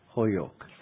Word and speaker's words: Holyoke: it's spelt Holyoke, but if you're former ambassador, you're expected to know that it's really Holyoke, Massachusetts. And Fred Sullivan, you're very welcome indeed.